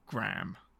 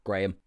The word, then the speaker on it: Graham: That is the English way of saying Graham. And his last name is G-R-A-H-A-M. – Graham. – But the way that the Americans say Graham is Graham.